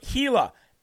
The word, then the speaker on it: Hila: Okay, it's pronounced Hila, H-I-L-A. That's how it's pronounced.